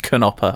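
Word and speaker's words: Knoppers: Does that say Knopper? – Oh, no, not Knopper. It's pronounced Knopper, I found out.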